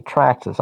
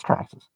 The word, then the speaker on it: Traxxas: Okay, I like the name Slasher, but yeah, you gotta say it right. Traxxas. I even know that. I'm not even an RC and I know it's Traxxas.